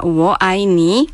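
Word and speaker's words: Wo-ai-ni (我爱你): Por eso se escribe 520 y se pronuncia Wu-a-ling, un sonido muy similar a Wo-ai-ni, que significa te amo.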